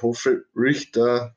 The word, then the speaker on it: Hofrichter: Und mit Sterling Hof, äh, Hofrichter, so spricht man es ja aus bei, die, äh, bei die Amis, ähm, hat man noch einen Panther in der siebten Runde geholt.